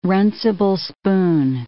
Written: runcible spoon

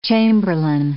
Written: chamberlain

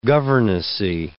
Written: governessy

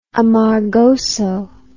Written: amargoso

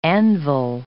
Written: anvil

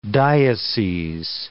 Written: dieses

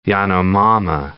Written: Yanomama

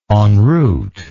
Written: enroute

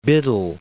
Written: Biddle

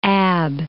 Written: ab

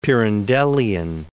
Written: Pirandellian

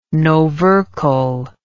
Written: novercal

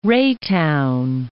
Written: Raytown

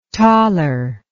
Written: thaler

